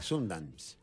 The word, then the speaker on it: Sundance: No sé si se dice así, Sundance, o de otra manera. – De Sundance, ¿no es? – Sundance. – Sí, Sundance.